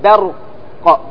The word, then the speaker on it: برق: كلمة برق لا تقول با، قل باء، فرق بين با وباء. برق، أنطق معي برق برق برق برق.